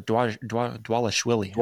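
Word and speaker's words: Dvalishvili: And he's going against a good dude, too, because Merab Dwalishwili. Or Dwalishwili, I think is what it is. – Yeah, I don't know. – Yeah, because I think they pronounce the V as a W.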